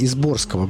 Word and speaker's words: Изборского: Кстати, вот вы являетесь членом Изборского клуба. – Чуть-чуть неправильное ударение. Изборского. Город Изборск.